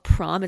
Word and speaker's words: Proem: It's by this company called Prom. It's spelled Proem, is who her designers worked for.